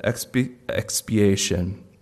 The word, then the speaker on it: expiation: And the other word sounds like it, but it's not the same. Another aspect of Christ's death is this word, expiation. And it rhymes with propitiation, but it's not the same thing.